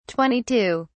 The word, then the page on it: 22: British English